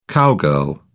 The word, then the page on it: cowgirl: British English